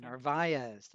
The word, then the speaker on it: Narvaez: I think I'm saying that right. – Narvaez, I think. – Narvaez, thank you.